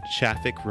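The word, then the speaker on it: Shafik: I already mentioned him once, but it's Davey Chaffick, Rhymes with Traffic, developer advocate at Akamai and release manager of PHP 7.1.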